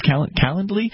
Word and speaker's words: Calendly: Calendly, I think is how it's pronounced, Calendly.